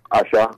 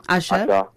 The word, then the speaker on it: Asa: My first one is do you pronounce it Usher? Azza? ASA? – Usher. – Usher. Yeah.